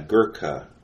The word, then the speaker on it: Gurkha: Yeah, Gurkha. I did pronounce it correctly. Gurkha. So it's like, ha, Gurkha.